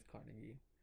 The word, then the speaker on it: Carnegie: The Carnage Hero Awards. – What? – Carnegie? I think it is Carnegie. Which I realized after saying Carnage, and then I'm like, is this going to be Carnegie?